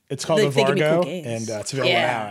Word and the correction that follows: Varjo: I love that we're getting away from the pronunciation of that product just Varjo, a little bit every single time we say it. It just gets, we get a little further away from it.